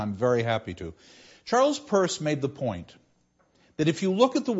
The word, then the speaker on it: Peirce: And he promoted an American philosopher by the name of Charles Peirce. It's spelled P-E-I-R-C-E, but pronounced Peirce,